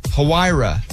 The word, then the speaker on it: Huayra: Huayra.